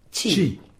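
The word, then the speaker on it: ĉi: Chi. – Très bien, chi. Ça s'écrit C chapeau I.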